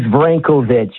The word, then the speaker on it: Vrankovich: It's Vrankovich. V for victory, because that's what we're going to get, right? – Mm-hmm. – R-A-N, because we're ran.